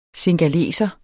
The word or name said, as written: singaleser